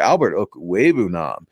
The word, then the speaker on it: Okwuegbunam: Albert Okwuegbunam has put together. See, I could finally get his name right.